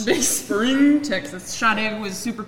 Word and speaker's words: Sade: That's the, and it's spelled S-A-D-E. – Yeah, not Sade. – Yeah. – Sade. – Sade.